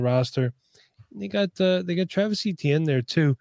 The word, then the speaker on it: Etienne: Let's talk about Travis Etienne.